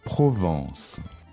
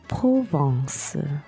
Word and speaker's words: Provence: Right now, all we're going to do is focus on the pronunciation, because the vowel is a little tricky in this one. – Yeah, I'm just going to say it clearly. – Mm-hmm. – Provence. – Provence.